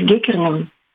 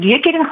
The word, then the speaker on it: Геккерн: А как, кстати, правильно произносить фамилию? Это тоже русифицированный вариант Геккерен? – Ну, мы произносим Геккерен, хотя иногда говорят о Геккерен, как будто бы два чтения возможны. – А Геккерн, когда теряется буква «Е»? Вроде как это ближе к голландскому.